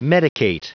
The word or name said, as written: medicate